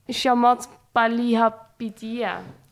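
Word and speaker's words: Chamath Palihapitiya: En ik denk een van de bekende SPAC-belegger is, het is Shamat Palihapitiya. Kan jij hem misschien uitspreken? – Ja. Oh, dit is een Indische naam. – Hij komt uit Sri Lanka. – Shamat Palihapitiya, dat is moeilijk.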